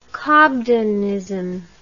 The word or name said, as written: cobdenism